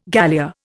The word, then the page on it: galea: Indian